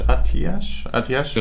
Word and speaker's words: Atiesh: Kampfschrei legt Attash. Nee, wie heißt die Waffe? Ich habe wohl das damals schon nicht aussprechen. Atiesh. Aber nicht mehr. – Wie ist Atiesh? Atiesh. Ja.